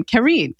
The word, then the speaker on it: Kareen: My mom wanted to spell it in a way that would be easy to phonetically read it. So it became Kareen, K-A-R-E-E-N. Well, then here in the States, definitely Karen is more familiar.